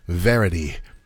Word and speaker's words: Verity: At the movies this weekend, Verity is the movie version of the Colleen Hoover book.